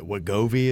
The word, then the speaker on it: Wegovy: People who have been going out and using things like the Ozempic and, is it Wegovy?